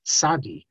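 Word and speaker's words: Sadi (צדי): And just like if you look at the Sephardim today, you look at the Syrians, when they write Matzah, they write M-A-S-A-H, sometimes with a dot under the S. Or, and that's because, you know, this T-Z we have, we pronounced it as Sadi, That's an Ashkenazic mispronunciation. Every group has mispronunciations, and we have a few as well. And there is no TZ in classical Hebrew. That's an Ashkenazic thing for a few hundred years.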